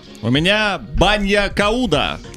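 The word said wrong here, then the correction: bagna cauda: Извините, у меня ударение банья кауда, вот так.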